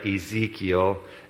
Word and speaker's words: Ezekiel: I want to invite you to your Bibles to Ezekiel. I said that right, Ezekiel.